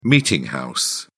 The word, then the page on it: meeting house: British English